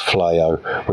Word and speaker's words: Fleaux: This is spelled F L E A U X. That's pronounced Flyo, which makes sense because I believe uh, Kobayashi is French.